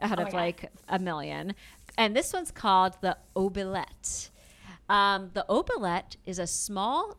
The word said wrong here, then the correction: oubliette: I don't know if I'm pronouncing that correctly. – Oubliette. – The L was in the wrong spot for me. It's oubliette. You're correct.